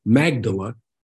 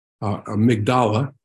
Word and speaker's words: Magdala: And at the bottom of that mountain is a town called Magdala, Migdala, depending on how you pronounce it.